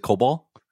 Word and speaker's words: COBOL: Is it COBOL? – Yeah, COBOL.